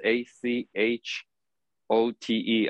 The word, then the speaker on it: achote: So I'll spell it as A C H O-T-E, achote.